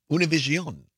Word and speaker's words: Univision: Vice President Kamala Harris was on Univision last night, or the pronunciation in Spanish, Univision.